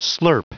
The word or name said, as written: slurp